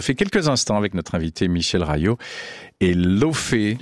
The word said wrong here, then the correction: Laufey: Leivey. – Comment ? – Elle est islandaise, Leivey. – Ça se dit comme ça ? – Ça se dit Leivey ? – Leivey. – Ça s'écrit L-A-U-F-E-Y, Leivey.